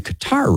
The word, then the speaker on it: Qatar: And I just want to credit Zach Seegers back there for saying Qatar right, because very few people do.